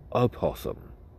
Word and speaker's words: opossum: Ladies and gentlemen, If you look to your left, out in the field, frolicking, there is a possum. I'm sorry, I mispronounced that. It is an opossum.